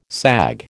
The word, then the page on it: sag: American English